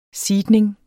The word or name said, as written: SEEDNINGEN